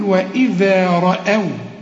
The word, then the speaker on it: رأوا: وإذا رأوا، هنا عايزة فن في النطق بقى، ما تقولش رأوا رأى رأوا، لا هنقول وإذا رأوا. شوف أو، واخد بالك، فخم لي بس الايه الراء.